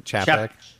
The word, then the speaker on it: Chapek: Chapek.